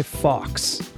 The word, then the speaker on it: Fawkes: Guy Fawkes. – What is that? – It's spelled F-A-W-K-E-S. And it looks like Fox.